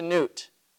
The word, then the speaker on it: Nut: One in particular in Egypt was one of their most ancient gods, a goddess named Nut, or Nut, N-U-T. Sometimes this is spelled Nuth, N-E-U-T-H, so it's probably pronounced Nut.